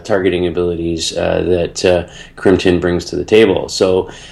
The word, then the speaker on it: Crimtan: Uh, uh, oh, sorry, uh, Crimtan. I think is how you pronounce it. Crimtan.